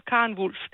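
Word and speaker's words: Karen Volf: Vi siger Karen Wulf, det gør jeg.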